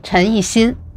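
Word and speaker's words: Chen Yixin (陈一新): Chen Xijin, ministro de Seguridad del Estado. ¿Cómo se dice? – Chen Yixin.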